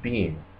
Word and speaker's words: Biehn: and Bob Bryan was drooling over him, and I'm pretty sure he said Bean. – He's got, but that's why he's not an A-lister, because he doesn't have that name that rolls right off. It doesn't sound like it's spelled, you know?